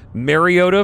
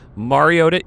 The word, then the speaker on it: Mariota: One from Marcus Mariota. That is how you say it. That's correct. Chris Collinsworth knows his stuff. Mariota, of course, is what most people call him. Mariota is the correct pronunciation, actually, of his last name.